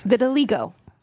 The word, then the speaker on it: vitiligo: Vitiligo. – Is that what it's called? – I just looked up. I said it right.